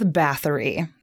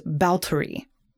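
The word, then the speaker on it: Báthory: So Elizabeth Bathory, that is actually not the correct way to pronounce her name, but it kind of sounds interesting in the way that she has been remembered throughout history as bathing in the blood of virgins, right? – Bathory. – But actually, in Hungarian, it's pronounced Bautory.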